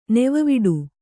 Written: ನೆವವಿಡು

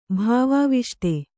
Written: ಭಾವಾವಿಷ್ಟ

